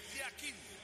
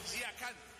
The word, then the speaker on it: Yakin: En el suizo se pronuncia así: Murat Yakin. En turco, Yakan. Cambia la última parte de su apellido.